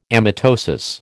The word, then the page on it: amitosis: American English